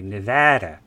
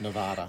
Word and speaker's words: Nevada: I hate to say it like that, but that's the way you're supposed to say it, apparently. Nevada. – Not Nevada.